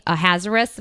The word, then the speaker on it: Ahasuerus: The kind of anglicized way is King Ahasuerus, which is probably what we'll be using.